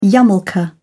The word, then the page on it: yarmulke: British English